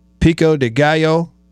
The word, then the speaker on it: pico de gallo: Mexican rice, pico de gallo.